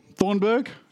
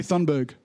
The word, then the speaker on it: Thunberg: Thornburg? Thunberg. It's definitely Thunberg. Thun? I don't know how to pronounce it, but it's not Thorn.